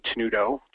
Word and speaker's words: Tenuto: It's just J Tenuto, just my name, J Tenuto, T-E-N-U-T-O,